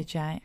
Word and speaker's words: Janne: J-A-N-N-E. Jane Robinson. – Yeah, Jane.